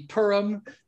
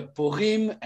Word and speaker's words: Purim: And in Israel, it's a very special holiday, which when American pronunciation would be Purim. In Hebrew, I think it would be something closer to Purim, and I might be totally wrong on that too.